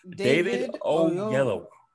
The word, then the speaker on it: David Oyelowo: Say it with me now. David Oyelowo. – God damn it. Oyelowo. – Come on, bro. Represent for right. – Africa. – David Oyelowo. I said it right. – Oh, what? – Oyelowo. – Yes, perfect.